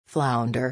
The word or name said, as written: flounder